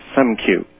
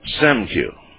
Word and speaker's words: Siskiyou: By the way, Art, it's pronounced Siskiyou. – Siskiyou. – Yeah, that's better.